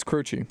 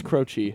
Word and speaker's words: Croce: And who I feel like his last name should be pronounced Croce. Like, if you look here. – Is it pronounced? I actually don't know. – Yeah, it's Croce. – It's Croce. – Mm-hmm. – The H is, there's no H, actually.